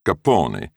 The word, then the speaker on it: Capone: At first, they continued to pronounce their surname phonetically, Capone, though that would soon change.